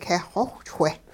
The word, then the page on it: keh xowhchwe': hupa